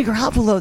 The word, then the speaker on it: Garoppolo: But I'm starting to think buyer beware on Jimmy Garoppolo though. Did I say his name right? – You did. The thing with Jimmy Garoppolo, there's so many terrible quarterbacks in the NFL.